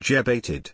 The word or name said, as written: jebated